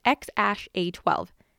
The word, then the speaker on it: XAEA-12: Musk says the name is pronounced X-A-12, pretty much just as it looks.